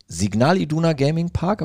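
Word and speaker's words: Signal Iduna Park: Jetzt sitzt äh, Luftlinie, glaube ich, 50 Meter entfernt Erne äh, hier ähm, in unserem äh, Signal Iduna Gaming Park, habe ich das richtig gesagt? – Signal richtig, Iduna Gaming ja, Park.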